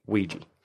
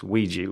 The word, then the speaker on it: Ouija: Because there's not much, I can't think of anything as weird as Ouija with those letters in that order. It just looks, it doesn't look how it's said in a way, does it?